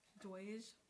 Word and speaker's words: Doge: And then, Doge, is that how you say it? – Doge, yeah.